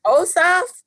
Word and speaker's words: Ossoff: I mean, that is significant in one way, but then Ossoff, is that how you say his name? – Yeah.